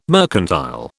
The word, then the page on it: mercantile: British English